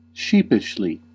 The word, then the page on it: sheepishly: /ˈʃiː.pɪʃ.li/